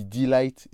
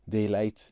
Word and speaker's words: Daylight: à savoir premièrement ma prononciation pour Daylight. J'ai dit Delight et non en fait c'était Daylight, le morceau de All the Nuts justement, j'en profite aussi pour corriger parce que j'ai dit donuts alors qu'il s'agissait de all donuts.